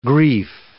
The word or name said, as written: grief